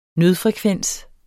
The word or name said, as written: nødfrekvens